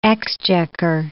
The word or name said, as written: exchequer